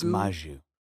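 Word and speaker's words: Mugu: M-U-G-U. I just say Magoo. Maybe I'm saying it wrong. Maybe it's Magoo. – Oh, it's Maju.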